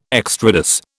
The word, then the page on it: extrados: British English